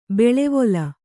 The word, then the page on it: ಬೆಳೆವೊಲ: beḷevola